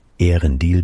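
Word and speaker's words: Eärendil: Ehrendil beginnt nicht mit einem Diphthong, hat am Wortanfang also eine Silbe zu viel.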